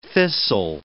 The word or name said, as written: thistle